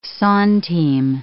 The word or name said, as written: centime